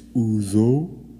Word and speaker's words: Ouzo: You got it right the second time. It's Uzo, spelled O-U-Z-O.